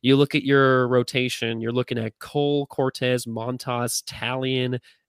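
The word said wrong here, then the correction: Taillon: It's Herman, and it's Tyone, Jameson Tyone, like a tie that you own.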